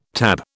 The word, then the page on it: TAB: British English